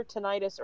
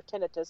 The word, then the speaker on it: tinnitus: including severe tinnitus or tinnitus, depending on how you pronounce it, mm-hmm.